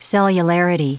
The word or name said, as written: cellularity